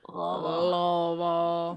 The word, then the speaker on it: Louisville: Y'all best say it right. – Say it like you got a lot of cotton balls in your mouth and you've had a lot of bourbon. – Louisville. – That's how you say it.